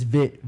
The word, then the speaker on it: Viet: So it's Vit, VIET, silent E. Vit, yeah, but just Trap. So yeah, just pronounce it Vit like.